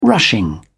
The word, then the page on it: rushing: British English